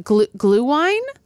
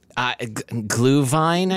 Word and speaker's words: Glühwein: Is it glühwein? – Glühwein, I think.